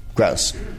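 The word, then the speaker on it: Grosz: How do you say it? – Gross. – Gross.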